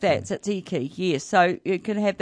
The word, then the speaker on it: tzatziki: and some tzatziki. Is that how you pronounce that? – What, the mushrooms? – No, the... – Tzatziki, is it? – Tzatziki.